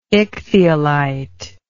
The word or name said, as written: ichthyolite